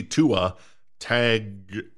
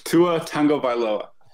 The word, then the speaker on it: Tua Tagovailoa: I want to see Tua... Tagovailoa. How do you say it? – Tua Tagovailoa.